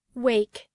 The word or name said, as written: wake